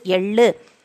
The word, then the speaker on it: எள்ளு: Ye illu yellu.